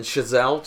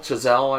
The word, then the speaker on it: Chazelle: They had an interview with, I believe, Damien Chazelle. Chazelle, I believe is how it's pronounced.